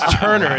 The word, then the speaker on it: Turner: Well, my name's Turner. It's impossible to pronounce. Two hard R's.